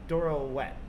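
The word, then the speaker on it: Doro Wat: So we're here to try Doro Wet. Is that right? – Yep, you said it right.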